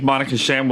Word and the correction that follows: Monique Shame: Monique, how do you say her name? – Monique Shame.